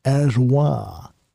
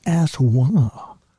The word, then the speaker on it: Aswad: I'm sure if you meet them at a cocktail circuit, they're introduced as Evelyn Aswad. The D is silent. Her name is not asswad, you cretin. It's asswad. The D is pronounced like an H.